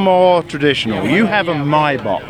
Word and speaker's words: Maibock: It's pronounced Maibock, but it does mean May.